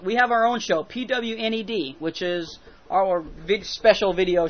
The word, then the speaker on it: Pwned: But we pronounce it Pwned, P-O-W-N-E-D. It's spelled P-W-N-E-D because of the transposition of the letters.